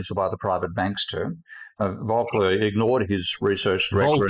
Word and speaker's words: Volcker: That was the somebody told me I was pronouncing his name wrong. It's Volcker.